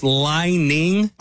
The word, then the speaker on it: Li-Ning: I believe the company, I think it's pronounced Lining. – I'm going to go with that. Yeah. – L-I-N-I-N-G.